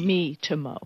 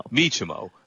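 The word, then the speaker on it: Miitomo: And I didn't ever use Nintendo's MyTomo. I'm not sure if I'm pronouncing it correctly. They had a social network. – Remember Nintendo Wii? – Me. – Nintendo MeTomo. – MeTomo. Okay, so the MeTomo social network.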